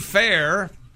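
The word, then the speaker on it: Phair: Casey Fair, it's spelled P-hair, though, has become the youngest player in Soccer World Cup history.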